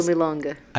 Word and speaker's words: Milonga: Milonga.